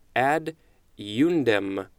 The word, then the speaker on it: ad eundem: So it's two words. Ad is the first word, and then eundem is the second word. Ad eundem. That is what the pronunciation is telling me.